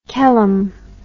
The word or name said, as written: Kelim